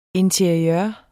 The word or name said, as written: interiør